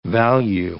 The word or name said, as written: value